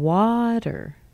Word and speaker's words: water: And water. Water. There's no T sound there.